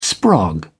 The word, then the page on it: sprog: British English